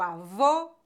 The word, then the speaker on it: avô: Quando pronunciamos o fechado, nossa boca fica mais fechada. Avô, bolo.